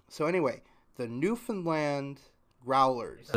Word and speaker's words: Newfoundland: I wanted to make sure that I pronounced it correctly. I pride myself on pronouncing things correctly whenever possible. And I have I very much have an affinity for Canada. I would love to go to Canada someday. So it is Newfoundland.